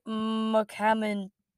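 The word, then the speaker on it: Mukhamadullin: What I think about this trade, and I'm just gonna correct every single name you said there. It's Zachary Emmond and Santeri Hatica and Timur Abragimov, and then San Jose got Fabian Zetterlund and Nikita Okorchuk and Shakir Mukhamadulin. That one I don't know how to pronounce.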